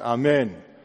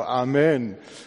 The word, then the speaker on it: Amen: Also es ist eigentlich ein hebräisches Wort und es wird auch auf der zweiten Silbe betont. Amen heißt das, also ein Grieche oder auch ein Hebräer sagt Amen.